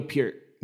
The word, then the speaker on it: Peart: proper way to pronounce his last name. I'm sorry, but is it Pierre? Pierre? It's Neil Peart. Neil Peart. Okay. Peart. Yeah, it's a little.